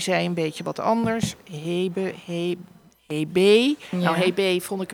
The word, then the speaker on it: Hebe: Maar wat ik mooi vind en wat volgens mij heel goed klopt is Hebe. Dus een beetje be en een beetje he.